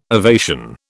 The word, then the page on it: ovation: British English